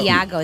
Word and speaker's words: Iago: It's no. Iago. It's an I.